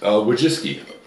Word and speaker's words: Wojcicki: Wojcicki.